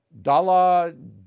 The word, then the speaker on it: Daladier: So Hitler, Neville Chamberlain, the Prime Minister of Britain, Edouard Daladier, I can never say his name, Daladier, Edouard Daladier.